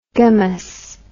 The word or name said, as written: gamous